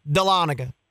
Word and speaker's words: Dahlonega: All right, so I'm saying it right, Dahlonega. – Yeah.